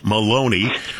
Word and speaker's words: Maloney: Let's start off with Pennsylvania Republican Congressman David rhymes with baloney, Maloney.